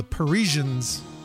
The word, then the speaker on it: Parisians: Out of Detroit on the Maltese label, the Parisians, Love on a Rampage. Looks like Paragents, but I know from another record of theirs how to pronounce it, because there's a little pronunciation key on the other label, not on this one.